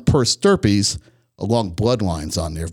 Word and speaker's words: stirpes: It's as simple as putting comma per stirpes, P E R S, and the new word S T I R P E S. Stirpes rhymes with something else.